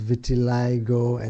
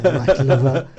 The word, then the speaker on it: vitiligo: And maybe someone sitting in a village in India couldn't pronounce vitiligo and said white liver.